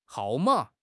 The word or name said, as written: hao4 ma3